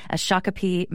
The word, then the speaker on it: Shakopee: Shakopee, Minnesota.